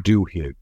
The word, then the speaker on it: Duhigg: And the New York Times did a nice article on it, and the author was Charles Duhigg, D-U-H-I-G-G. I think that's how you pronounce his name.